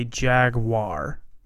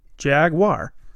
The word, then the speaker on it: Jaguar: It's perfectly fine to say Jaguar. Jaguar, right? Jaguar.